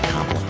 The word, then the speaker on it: Kamala: Except it's pronounced Kamala.